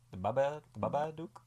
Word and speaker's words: Babadook: Is it the Babadook or the Babadook? – He says Babadook. – I think he just says Babadook, right? – He says Babadook, yeah.